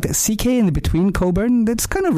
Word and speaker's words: Cockburn: Or even, you know, going, if you take the freeway down south and you see like Coburn. – It's Cockburn.